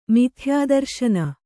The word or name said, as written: ಮಿಥ್ಯಾದರ್ಶನ